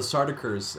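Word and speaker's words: Sardaukar: I know that they're described of killing the how do you say it? The Sardaukins? Sardaukers? – Oh, the Sardaukers, Emperor Padishah's elite royal army.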